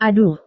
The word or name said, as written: aduh